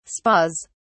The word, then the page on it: spas: British English